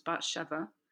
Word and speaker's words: Bathsheba: So Bathsheba think the proper pronunciation is Bathsheba.